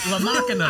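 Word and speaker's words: La Maquina: La Macquina. – I got to say how you say it on the album.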